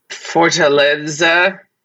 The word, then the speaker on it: Fortaleza: It's called Fortaleza. That's how you uh-huh. pronounce it because I'm Mexican. So that means if you guys pronounce it, that you're doing it wrong.